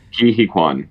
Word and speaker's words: Ke Huy Quan: Uh, did I say his name right? – Ke Huy Quan. Yeah.